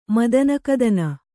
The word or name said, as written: ಮದನಕದನ